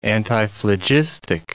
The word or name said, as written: antiphlogistic